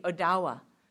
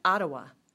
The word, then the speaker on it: Odawa: In northern Michigan, we have the Odawa. I always heard it as Ottawa. It's spelled different, it's pronounced different, and actually they fight amongst themselves about what's the correct pronunciation, what's the correct spelling.